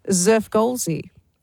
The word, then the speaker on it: Xfrgolszzzxy: Well, it is spelt X-F-R-G-O-L-S-Z-Z-Z-X-Y. She says it's pronounced Zerfgolzi.